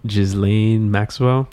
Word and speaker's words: Ghislaine Maxwell: Ghislaine Maxwell.